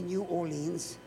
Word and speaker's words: New Orleans: You're taking a Delta flight from New Orleans, say New Orleans. Say it like me. Say New Orleans. That's better.